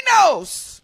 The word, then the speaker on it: Noce: Mindy Nose. Capital N-O-C-E. Is that the right way to say it? Nose? Is that correct pronunciation? – Yeah. – Mindy. Mindy Nose.